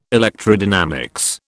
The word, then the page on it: electrodynamics: British English